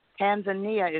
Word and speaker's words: Tanzania: Tanzania is where it should be pronounced.